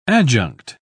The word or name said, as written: adjunct